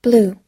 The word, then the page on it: blue: long-u